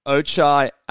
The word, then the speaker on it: Ochai: Um, so my 11 and 12, I'm going to probably butcher this name, but um, Akai Agbaji. Um, I believe it's um, Ochai Ag- Ajabaji. Okay, there we go.